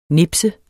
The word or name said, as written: nipset